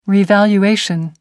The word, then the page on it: revaluation: American English